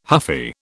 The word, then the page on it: huffy: British English